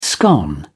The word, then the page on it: scone: British English